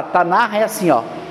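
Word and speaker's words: Tanakh: Tanar. É um R com H, sabe? É um então, toda vez que você a palavra hebraica que tem um Hzinho no final, você pronuncia Tanar.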